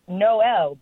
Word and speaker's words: Mnop: So, the name is Noelle, and that doesn't sound too crazy, except it was spelled M-N-O-P. – What? – What? – Yes. So, when you look at it, it's spelled M-N-O-P, but it's pronounced Noelle because when you look at the alphabet, it's L-M-N-O-P.